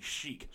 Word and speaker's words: Chic: I believe that's how you pronounce it. – That's what I would say. – Yeah, C-H-I-C, Chic.